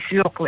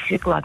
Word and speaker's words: свёкла: Это первое, свёкла или свекла, например, как многие говорят.